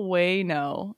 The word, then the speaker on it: Wano: Way, like a whale, Wano. There's a hidden H in there, Molly.